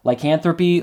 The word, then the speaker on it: lycanthropy: Lycanthropy? Lycanthropy? – It's lycanthropy. I listened to a person talk about it online.